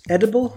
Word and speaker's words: EDBL: There's a young guy, I think it's pronounced Edible, right? – EDBL.